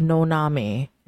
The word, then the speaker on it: Noname: And a whole month, my name was Noname. And if you can't understand that, it's spelled N-O-N-A-M-E. No name.